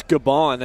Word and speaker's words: Gabon: I'd say it's Gabon, and it was actually 9 years ago that they found them, back in 2008.